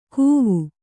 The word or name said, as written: ಕೂವು